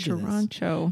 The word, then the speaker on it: Toronto: You don't pronounce that second T. I told Toronto.